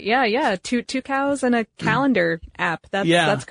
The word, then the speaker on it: Tucows: Yeah, two cows. – Two cows? – Yep. Yeah, they have two cows, twice as many cows as someone who has one cow. So yeah, except it's spelled T-U-C-O-W-S.